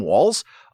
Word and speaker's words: Walz: First, that you pronounce his name Walz.